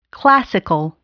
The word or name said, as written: classical